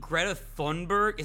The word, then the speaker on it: Greta Thunberg: So, I don't know exactly what started it, but Andrew Tate got into some Twitter beef with fucking Greta Thunberg.